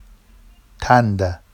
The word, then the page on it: tanda: Vafsi